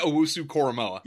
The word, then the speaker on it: Owusu-Koramoah: Jeremiah Owusu-Koramoah. Linebacker. – There you go. Yeah. Do say it again there. – Jeremiah Owusu-Koramoah.